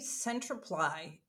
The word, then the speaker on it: Centriply: Our name, Centriply, it's pronounced like clarify, amplify, simplify.